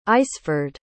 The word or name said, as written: aisford